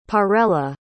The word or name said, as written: parella